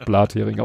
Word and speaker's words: Blathering: Ich spreche seit der ersten Folge das Blathering aus. Das ist der Gag, dass wir uns äh, Deutsch aussprechen, als wäre es eine deutsche Fischsorte.